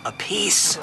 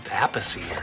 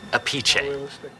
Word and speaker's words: Appice: That is Carmine Appice. – Oh. Hey, wait, I thought it was like pronounced Appice or something like that. – Technically, if it's the Italian pronunciation, it's Appice.